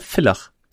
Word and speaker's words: Villach: Entschuldigung, wir haben es letztes Mal falsch ausgesprochen, wir haben Ärger bekommen, ne? Villach. Weil ja V, kein W.